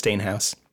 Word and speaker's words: Steenhuis: I have a hard to say name. It's spelled Steenhuis. No one thinks it's Steenhuis,